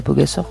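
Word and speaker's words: Pogačar: Est-ce que c'est Bernard Hinault, Marc Cavendish ou Tadej Pogacar? – C'est Marc Cavendish, d'accord. Je sais pas bien prononcer, là, Pogacar. – Pogacar.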